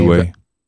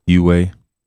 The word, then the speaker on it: Huawei: Huawei. – Huh? – Huawei.